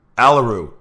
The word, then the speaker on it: Aleru: Did I say your name right? Aleru? – Aleru. – Oh my gosh. I'm so sorry.